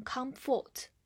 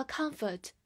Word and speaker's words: comfort: Comfort, C O M F O R T, comfort, C O M 发 com，F O R T 发 foot，因为这个单词的重音在第一个音节上面 com。所以后半部分是弱读，我们发 a 这个音，所以是 comfort，comfort 不要读成 comfort。好，它是一个名词，表示安慰或者舒适。造一个句子：It's a comfort talking to you。